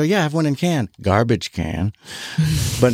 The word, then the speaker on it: Cannes: When I was in Cannes for, and it is pronounced Cannes. – Yes. – I was like, Cannes. No, it's Cannes.